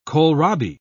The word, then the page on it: kohlrabi: British English